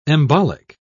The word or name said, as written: embolic